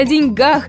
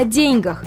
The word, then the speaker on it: деньгах: Во времена Достоевского, Толстого, Чехова единственно верным вариантом было ударение на первый слог. А значит, деятели века 19-го говорили именно о деньгах. О деньгах уже заговорили в веке 20-м, а в 21-м традиция ставить ударение на последний слог только укоренилась.